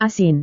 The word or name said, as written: asin